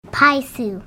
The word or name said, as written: paysu